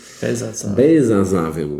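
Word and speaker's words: Balthasar Wermut: Es gibt einen deutschen Balsasser, Balsasser Wermut. Ich weiß es nicht. Ich muss nachschlagen. Ich kriege es immer falsch ausgesprochen. Es tut mir auch leid. Balsasar-Wermut, Entschuldigung.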